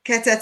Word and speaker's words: Kether: one magical movement from Kether to Malkuth." – Thanks. Bye. – Did I pronounce that right? – Keter to Malkut, but yeah.